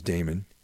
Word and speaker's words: Daemon: Like in the occult world, there's like demon, D-E-M-O-N. – Right. – Damon, which people pronounce Damon, D-A-E-M-O-N. And then Daimon, D-A-I-M-O-N.